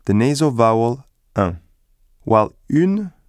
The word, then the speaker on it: un: Let the air go through both your mouth and your nose when you pronounce un. Don't pronounce the letter n.